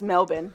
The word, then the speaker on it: Melbourne: Apparently it's pronounced Melbourne, by the way. I thought it was Melbourne.